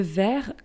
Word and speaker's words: vert: Le vert, V-E-R-T, is green. For instance, uh, a green t shirt, un t shirt vert, but it's with a T at the end, we just don't pronounce it.